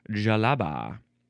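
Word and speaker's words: djellaba: You pronounce it Jalaba.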